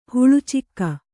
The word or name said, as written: ಹಳಚಿಗೆ